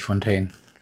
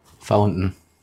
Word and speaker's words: Fountain: Nee, nicht Fontaine. Also das spricht man sicher nicht Fontaine aus. – Wieso nicht? – Weil das Englisch ist. – Was dann? Das andere ist ja Fontäne. Also halt, oder halt, also ich ist ja Quelle. – Also erstmal ist es der Brunnen. Fountain. – Fountain. – Fountain. Ja. Fountain. – Aber schreibt man es nicht gleich? – Nee. Wie? Fontaine. Fontaine ist es auf jeden Fall nicht.